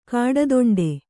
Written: ಕಾಡದೊಂಡೆ

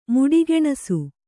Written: ಮುಡಿಗೆಣಸು

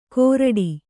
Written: ಕೋರಡಿ

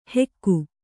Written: ಹೆಕ್ಕು

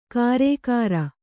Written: ಗಾರೆಕಾರ

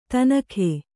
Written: ಥಣ್ಣಗೆ

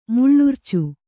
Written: ಮಿಳಿರ್ಚು

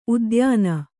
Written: ಉದ್ಯಾನ

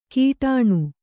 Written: ಕೀಟಾಣು

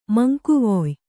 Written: ಮಂಕುವೊಯ್

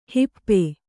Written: ಹಪ್ಪು

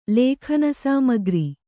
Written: ಲೇಖನಸಾಮಗ್ರಿ

